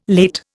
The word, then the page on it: late: Indian